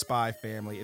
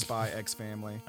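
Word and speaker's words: SPY×FAMILY: You know goddamn well it's just Spy Family. It's not pronounced Spy X Family.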